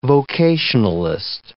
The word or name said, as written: vocationalist